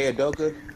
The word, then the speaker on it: Udoka: Ime Udoka.